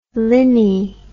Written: linnhe

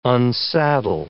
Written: unsaddle

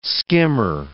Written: skimmer

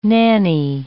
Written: nanny

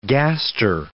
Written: gaster

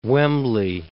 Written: Wembley

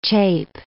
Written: chape

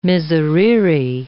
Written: miserere

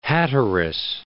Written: Hatteras Island

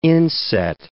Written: inset

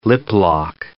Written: lip-lock

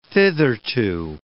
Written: thitherto

